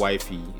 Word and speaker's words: Wyfi: It's called Wifey. It's spelled as W-Y-F-I.